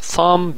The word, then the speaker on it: thumb: thumb بیش تلفظ نمیشه، میشه شست.